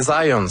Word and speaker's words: Zajonc: c'est Zajonc. – Ouais? OK. – J'ai-tu pas dit Zajonc? – Ben, j'avais dit plus Zajonc, mais... – Ah, parce que j'ai prononcé un petit peu à l'anglais. J'ai prononcé mon polonais à l'anglais.